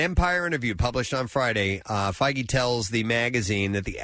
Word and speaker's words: Feige: We've pronounced it Feige and Fague and Feige, and I think it's Feige.